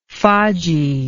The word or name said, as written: faggi